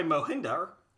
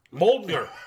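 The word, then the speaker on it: Mjölnir: Revealing that Hela managed to destroy Mohinder. – Mjolnir.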